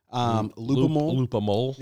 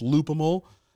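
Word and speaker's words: Loopimal: Loop-a-mole. – Yeah, like animal, but with loop. So it's Loop-a-mole. And I believe that I'm saying it right.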